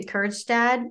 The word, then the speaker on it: Kjerstad: So there was Heston Kjerstad, which I always have trouble saying. – Well done. – Did I do that? – I would have definitely pronounced the J.